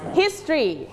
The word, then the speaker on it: history: เรื่องราวในอดีตนะคะเรื่องราวในอดีตคือวิชาอะไรเราเรียนวิชานี้ในภาษาไทยเรียกว่าอะไรประวัติศาสตร์ถูกต้องไหมคะแต่ในภาษาอังกฤษออกเสียงว่า history. – History. – History. – Only two syllable.